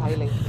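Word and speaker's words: Hailey: And she couldn't call me Bailey's, she called me Hayley.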